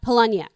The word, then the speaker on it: Palahniuk: Fight Club novelist Chuck Pala. Do you know how to say his name? – Polonia. – Okay. Fight Club novelist Chuck Palahniuk was a fan.